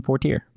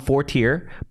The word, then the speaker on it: Fortier: But he goes, people call him Fortier, but it's traditionally pronounced Fortier.